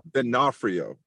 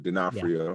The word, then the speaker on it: D'Onofrio: So what's the correct pronunciation? What's the correct pronunciation? – D'Onofrio. – Gotcha. D'Onofrio.